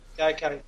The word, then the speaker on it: Gaikai: Is it called Gaikai, or how do you pronounce it? – Yeah, Gaikai, yeah.